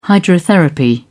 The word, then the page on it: hydrotherapy: British English